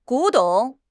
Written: 古董

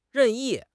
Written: 任意

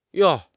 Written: yong4

